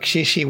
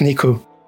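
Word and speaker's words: niku (肉): So they go into the entire thing where, like, shishi was how you once read the word that currently, for meat, that's currently pronounced niku.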